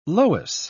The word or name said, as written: loess